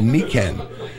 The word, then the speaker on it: Nikken: And the other shared information about a company called Niken. It's spelled Nikken, that's in Irvine, California.